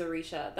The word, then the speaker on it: Aricia: I think it's my name is Arisha. That's my Instagram. I'm pretty sure it's my Twitter. But it's my name is, and my name is spelled Aricia. Like Alicia Keys, but with an R.